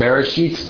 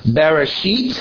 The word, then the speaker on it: Bereshit: Okay, that's going to be the one that's pronounced Bereshit, Bara, and so forth.